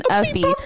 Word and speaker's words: Uffie: Right now, when you turn this episode off, head on over to YouTube and type in Uppy. That's UFFIE. Am I correct? That's Uppy. Mm-hmm. That's how it's spelled. UFFIE. Uppy. The song is called Pop the Glock. So put in Uffy, Pop the Glock.